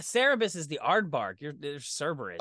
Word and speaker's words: Cerberus: I keep thinking Cerebus is the three-headed dog, but I think I'm mispronouncing it. – No, that's Cerberus, dude. – See, I've been mispronouncing it.